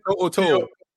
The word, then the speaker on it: To'o To'o: To'o To'o.